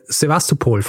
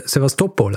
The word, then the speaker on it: Sevastopol: Übrigens, jemand hat mir auch geschrieben und hat mir gesagt, dass ich Sevastopol falsch ausgesprochen habe. Mhm. Weil das spricht man wohl Sevastopol aus.